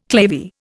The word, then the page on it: clavier: Indian